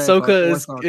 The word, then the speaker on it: Ahsoka: Ahsoka is it, yes. – Ahsoka's right. – Man, Ahsoka is correct, yeah.